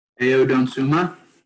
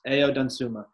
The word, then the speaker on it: Ayo Dosunmu: Ayo Dosunmu. – Yes, Ayo Dosunmu.